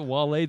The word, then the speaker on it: Wale: Yeah, well, another side note, Wale, is that how you say it? Wale the rapper? – Wale. – I didn't mean to say the Pixar character.